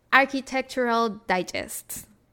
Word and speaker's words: Architectural Digest: Ha aparecido en la revista esta, y lo voy a pronunciar súper mal, pero es la Architectural Digest. Ay, sí lo pronunciaste bien, mamona.